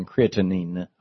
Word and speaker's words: creatinine: And so I asked, I mispronounced it as creatine rather than creatinine, I guess.